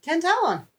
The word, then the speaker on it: Tantallon: How do you pronounce it then? – Tantallon. – That's what I said. – Yeah. Oh. You said Tantallon. – All right. Tantallon.